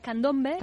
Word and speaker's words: candombe: Como el tango, el vals criollo, la milonga, la murga, el candombe. ¿Se dice así, candombe? – En realidad es candombe, candombe.